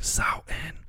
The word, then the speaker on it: Samhain: It's spelled Samhain, but at least here it says you're supposed to pronounce it like Samhain. So it's Samhain.